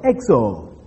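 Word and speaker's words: Exo (ἔξω): Now, the word outsiders, interestingly, comes from a simple Greek word, exo. Exo. Now, sometimes I write these things, but it is E-X-O. That O is a long O in the Greek.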